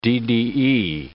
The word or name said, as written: DDE